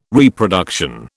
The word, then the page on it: Reproduction: British English